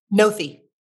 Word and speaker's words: Noethe: It's spelled N-O-E-T-H-E. – And it's Noth, not Nothe? – I think it's Noeth.